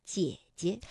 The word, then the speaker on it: 姐姐: 那么，姐姐本来啊，这个赏声也应该读。 214，姐。但是当连读的时候，第一个姐呢，我们就把它读成 21，姐姐。